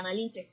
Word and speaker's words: Malinche: Entonces los, eh, los españoles no podían eh, pronunciar el sin, T-Z-I-N, y entonces lo pronunciaban che. So ahí está Malinche.